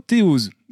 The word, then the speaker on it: Théose: Théose. Je le prononce à la française, mais peut-être que c'est pas la bonne prononciation. Si, si, c'est exactement ça.